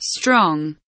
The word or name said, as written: strong